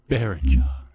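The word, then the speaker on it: Berninger: Berenger.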